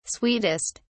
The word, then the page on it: sweetest: British English